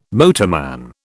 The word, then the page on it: motorman: British English